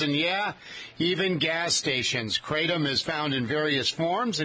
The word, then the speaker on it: kratom: I've called it kratom before, but it's spelled K-R-A-T-O-M. I guess it's called kratom.